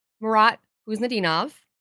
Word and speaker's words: Murat Kuznetsov: But one of the most important things, and in my last podcast, I talked about how Murat Kuznetsov, there you go. See, I'm getting better and better at it. I wrote it down how I'm supposed to pronounce it on my paper so I don't look like an idiot again.